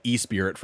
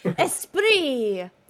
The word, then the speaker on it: Esprit: No, I kept telling myself, like, okay, before we start the show, I need to double check the pronunciation of this, because otherwise we're going to say, like, E-Spirit from IBM Business or something. Like. – Esprit. That's how I've always said it in my head.